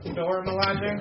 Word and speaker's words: Dora Milaje: Your wife looks like one of the Doja Milaje. I don't even think I said that right. – Dora Milaje? – Yeah, what you said.